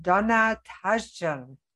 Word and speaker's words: Donna Tashjian: And today I'm very excited to have Donna Tashjian.